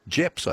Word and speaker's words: JEPS: You pronounce that JEPS, I guess, would you, Warren? Or GEPS? – We do.